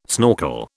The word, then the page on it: snorkel: British English